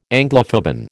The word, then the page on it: Anglophoben: American English